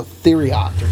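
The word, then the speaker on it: Thieriot: And then it's T-H-I-E-R-I-O-T, so Theriot.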